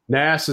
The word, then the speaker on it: Nass: Nass is how it goes.